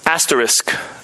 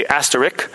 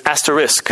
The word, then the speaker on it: asterisk: And it's pronounced asterisk. You might say asterisk, but it's asterisk.